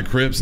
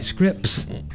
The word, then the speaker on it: Scripps: There's an S at the beginning and the end, but no T, oddly enough. It sounds like Scripps.